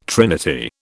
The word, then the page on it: trinity: British English